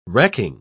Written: wrecking

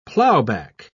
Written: plowback

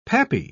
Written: pappy

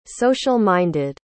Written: social-minded

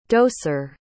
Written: doser

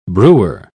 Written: brewer

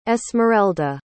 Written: esmerelda

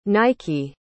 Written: nyeki

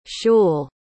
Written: schul

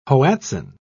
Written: hoatzin

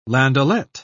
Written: landaulet

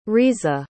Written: riza

